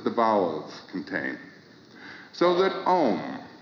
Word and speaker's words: Om: Now, this sacred syllable Om, which is repeated in all of the prayers of India, is composed of four sounds.